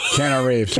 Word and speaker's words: Keanu Reeves: He kind of reminds me of an older, I don't know how to say his name, Keanu Reeves? – Keanu Reeves.